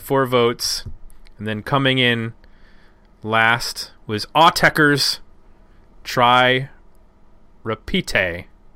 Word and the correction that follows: Autechre: I think it's Autechre. – Repita. – I think Autechre is how you pronounce the band name.